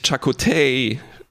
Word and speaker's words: Chakotay: Und ach ja, ganz wichtig, Chakotay war die richtige Aussprache.